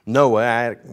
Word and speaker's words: Noahic: This is the, if you want to call it Noah covenant, I don't know how you say it, N-O-A-H-I-C, Noahic. Sounds like I got something in my back.